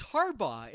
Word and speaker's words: Harbaugh: Well, now that it's football season, the listeners are going to have the pleasure of hearing Mully pronounce the name of the Michigan head football coach as Jim Harbaugh. What? What is that? It's Harbaugh, isn't it? Jim Harbaugh.